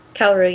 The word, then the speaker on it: Kaoru: won't they tension sexual relationship with Kenshin and Kaoru. Is that how you would pronounce her name? – Kaoru, yeah.